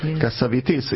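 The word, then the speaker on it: Cassavetes: Tudod, hogy hogy kell Kasszavetesz nevét kiejteni? Kasszavitis. Igen, én mindig Kasszavetesznek mondtam. – Én is Kasszavetesznek mondtam. – Igen, egy amerikai barátnőmtől hallottam. – Kiejtette, és így kell.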